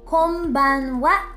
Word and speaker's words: こんばんは: You may want to take note that when the n, that's the n,character is followed by b, p, and m sounds, it's more naturally pronounced as m, that's an m. That's why, although it's spelled with the n, the n character, it is pronounced konbanwa naturally.